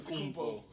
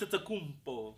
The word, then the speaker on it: Antetokounmpo: Antetokounmpo. – He loved Antetokounmpo.